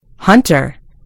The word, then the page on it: hunter: British English